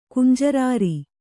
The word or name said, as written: ಕುಂಜರಾರಿ